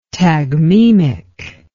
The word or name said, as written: tagmemic